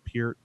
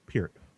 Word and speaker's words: Peart: Is that how you pronounce Neil? – Peart. – Peart? – Peart. – Oh, my God. I've been saying Peart my whole life.